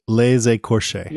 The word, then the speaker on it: Les Écorchés: Seven. Les Escorches? – I would say les écorchés, Les Escorches.